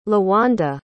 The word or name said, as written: lawanda